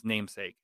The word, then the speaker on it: NMSK: So it's going to be N-M-S-K, but it's going to be pronounced Namesake.